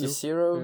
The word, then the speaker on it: Jezero: Ja, det er det i hvert fald Jezero, nu.